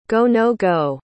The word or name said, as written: go-no-go